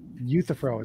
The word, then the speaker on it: Euthyphro: That's why I said Euthyphro is how you say it.